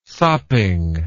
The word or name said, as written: sopping